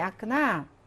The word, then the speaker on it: окна: Маленькие окна. Окна. 오크나죠? 강세가 O에 있습니다. 그래서 O 발음 나고요.